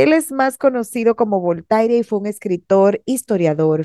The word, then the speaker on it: Voltaire: Yo le digo tal cual se escribe, Voltaire.